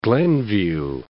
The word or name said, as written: Glenview